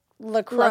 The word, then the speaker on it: LaCroix: And we did just confirm that it's pronounced LaCroix. – LaCroix. – Even though I want to say LaCroix.